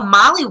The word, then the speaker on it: Mali: They're my first brand sponsor is Molly Wilson. So if people go, Molly Wilson, I can't find her, it's because my name is spelled M-A-L-I Wilson. And I was Melissa Molly Hunter, M-A-L-I-S-S-A.